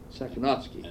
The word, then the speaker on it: Sakhnoffsky: Sakonofsky, S-A-K-H-N-O-F-F-S-K-Y, Sakonofsky.